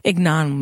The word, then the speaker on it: ignominy: Ignominy. – Like ignoramus. – Ah, yes. Ignominy. – Or ignorant. – There you go. That's how you pronounce that word, audience.